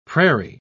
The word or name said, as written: prairie